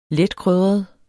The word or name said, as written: letkrydret